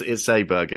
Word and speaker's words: Åberg: Yeah, I mean, obviously, Ludvig Aubrey, Aberg. There's a lot of people anticipating a lot of discussion on how we're going to pronounce it. It's Aberg. It's not.